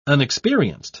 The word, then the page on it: unexperienced: British English